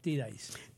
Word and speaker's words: T-Dice: Eso se escribe Te Dice en español, pero en inglés le llamamos T-Dice.